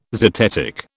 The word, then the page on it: zetetic: British English